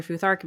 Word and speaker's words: Futhark: So you mentioned that you work with the Elder Futhark. Am I saying that right? Okay, Correct. Perfect.